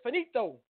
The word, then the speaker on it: finito: Two, I'm saying it wrong. It's not finite. It's finito. I think it's finito.